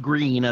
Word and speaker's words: Gruene: green is not spelled green at all. It's spelled something like, is it Gruene? – Yes.